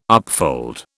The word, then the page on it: upfold: British English